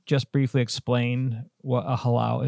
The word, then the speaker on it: halau: And she is also a member of the world-renowned Halau o Kekuhi, based right here in Hilo.